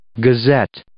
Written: gazette

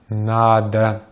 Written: надо

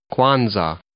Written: Kwanzaa

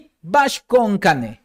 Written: BA'AX KO'ON KANEJ

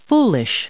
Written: foolish

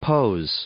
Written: pose

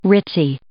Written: ritzy

